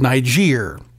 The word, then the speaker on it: Niger: Donnie's Benghazi is called Niger, which worries me because I know a lot of people are pronouncing it with a completely different G sound. Don't do that.